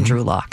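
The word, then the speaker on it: Drew Lock: So we need a heavy hitter in the front seven because we're good in the QB position with Geno Smith and Drew Locke. Did I say that right? – You did it.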